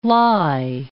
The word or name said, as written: lye